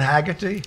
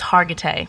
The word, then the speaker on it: Hargitay: It's not Haggerty. – It's Hargitay. People mispronounce it a lot.